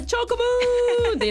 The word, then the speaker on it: チョコムーン: スタジオにはイラストレーターのチョコムさんです。言い方合ってます。 – 合ってます。 – 発音はチョコムーンでいいのかな。 – 合ってます。 – チョコムーンさんです。